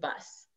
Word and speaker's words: Buss: And then you can find me on Facebook at Elisa, and that's spelled Elysia, and then Jane, Jane, and then last name Bus, Buss. Just like a school bus, just bigger, like my giant personality.